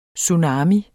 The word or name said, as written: TSUNAMIER